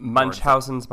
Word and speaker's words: Munchausen: It's Munchausen's by proxy.